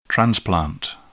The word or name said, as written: transplant